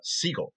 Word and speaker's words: sigil: But you also had this second word, sigil. Is that how you pronounce it? – Sigil. It's a soft G, yeah.